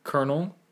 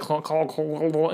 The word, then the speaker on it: Colonel: that was spelled the right way, or spelled not even, it's spelled this way, Colonel. But colonel. Like, my dad was just like, I was trying to be like, call, and he's like, no, Colonel Mustard.